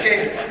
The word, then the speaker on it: Javins: How do we pronounce Marie's last name? – Javins. – Different A. Javins.